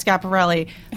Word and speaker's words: Schiaparelli: It's Schiaparelli.